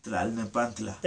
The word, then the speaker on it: Tlalnepantla: Arena Lopez Mateos, yep, in Tlalnepantla. That's terrible. Say it. – Tlalnepantla. – There you go. Yeah. See, authentic pronunciation.